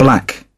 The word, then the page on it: black: British English